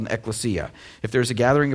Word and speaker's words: ekklesia: When the word church is used in the New Testament, it's a Greek word that's pronounced ekklesia.